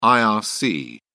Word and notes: irc: British English

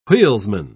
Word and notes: wheelsman: British English

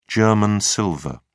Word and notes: german silver: British English

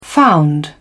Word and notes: found: British English

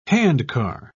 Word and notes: handcar: British English

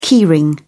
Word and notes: key ring: British English

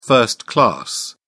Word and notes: first class: British English

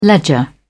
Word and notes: ledger: British English

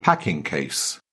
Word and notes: packing case: British English

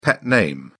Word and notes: pet name: British English

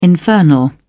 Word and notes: infernal: British English

